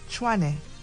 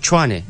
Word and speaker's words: Tshwane: Tshwane. – Is it Tshwane? – Yes.